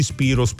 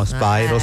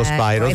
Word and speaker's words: Spyro: Noi subito Spyro, tutti Spiro. No, Spyro, Spyro, cioè, le televisioni Spiro.